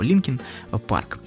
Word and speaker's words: Linkin Park: Дело в том, что на сленге местном Линкольн произносится как Линкин. Ну и, в общем, так и получилась эта группа Linkin Park.